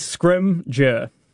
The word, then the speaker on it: Scringer: How do you say your surname, sir? – So it's pretty simple. Mm-hmm. Two syllables. Yep. Don't overthink it. – Yes. – Scringer.